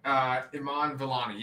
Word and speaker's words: Iman Vellani: Iman Vellani? – Oh, yeah. Iman Vellani.